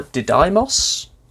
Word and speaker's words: Didymos: because it was discovered orbiting the asteroid of Didymos, or Didymos. It's spelled D-I-D-Y-M-O-S.